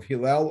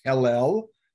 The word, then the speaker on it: Hillel: One, the follower of Hillel, or properly pronounced Hillel, although don't tell the folks at any of the schools or organizations, but the proper pronunciation is probably Hillel.